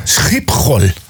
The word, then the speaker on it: Schiphol: Ja, det låter som en harkling. Mm. Schiphol.